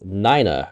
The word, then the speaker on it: niner: And finally, why in the NATO phonetic alphabet do you pronounce the number 9 as niner rather than saying just 9? – So that's to avoid confusion with saying 5.